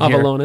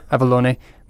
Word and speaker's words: Avalon: They referred, and I'm trying to remember exactly what it is, but Avalon, or I don't know how it's pronounced in Avalone. – Here. Avalone.